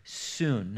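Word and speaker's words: soon (σύν): And the prefix of that Greek word is the word soon. Now, if you're taking notes, we would spell that in our English alphabet, S-Y-N, but we'd pronounce it soon.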